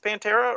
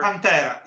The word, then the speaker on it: Pantera: Is that how you say your name? Is Pantera? Or Pantera, yes. Okay. Well, I say it like the Italian word, but it's Pantera is correct too in English. In Italian, it just sounds Pantera. Okay. But that's because it's an Italian word.